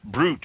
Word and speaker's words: brut: Brut, B-R-U-T, the most common style of champagne. You find this all over the place. Pronounced brut, B-R-U-T.